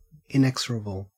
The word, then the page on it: inexorable: Australian English